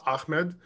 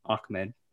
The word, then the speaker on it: Ahmed: And he asked Laird how to pronounce his name, and Laird said Savon Ahmed.